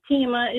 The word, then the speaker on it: Teima: Hi, my name is Tima. It's spelled T-E-I-M-A. And I absolutely love my name. My mom, we all have unique names. But all through school, my teachers always mispronounced my name. Taima, Tiaima, Selma.